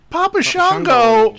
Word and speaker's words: Papa Shango: Because of the Papa Jungo, right? That's how you say it? – Papa Shango Jungo.